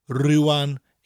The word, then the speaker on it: rwan: It's cold at the moment, or now, nawr in South Wales, rwan in North Wales.